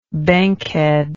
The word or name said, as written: bankhead